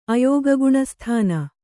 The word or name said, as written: ಅಯೋಗಗುಣಸ್ಥಾನ